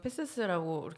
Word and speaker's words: PCC: 그 거기 바 이름이 그래서 패세스라 그래서 원래 PCC 파리 컨트리 클럽인데 불어 발음으로는 패세스라고 이렇게 부르는데 거기를 간대요.